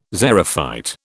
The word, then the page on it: xerophyte: British English